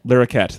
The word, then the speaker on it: Larroquette: Larroquette. – That's how you say it.